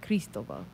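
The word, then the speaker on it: Cristóbal: Cristobal. Actually, what? If you really want to say it right, Cristobal.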